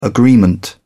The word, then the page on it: agreement: British English